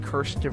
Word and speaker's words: Kirsten: All credit to Kristen Weir. Hopefully I'm pronouncing that right. – It's Kirsten Weir. Yeah. – Oh, yeah, it is Kirsten. Not Kristen.